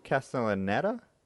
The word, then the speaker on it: Castellaneta: You can probably pronounce that more correctly. Castellaneta? – I'm fairly sure it's, yeah.